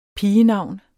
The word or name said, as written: pigenavn